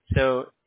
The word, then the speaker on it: Seo: All right, so first name, John, J-O-N. Last name is So. It's spelled S-E-O.